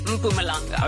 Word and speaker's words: Mpumalanga: They educated me and said it's Mpumalanga.